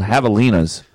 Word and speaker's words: javelina: They're called javelinas. – That's the name. – But it's spelled javelina because they're Spanish.